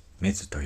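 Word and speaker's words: 馬頭: メズというのは、馬、漢字の馬と頭と書いてメズと読みます。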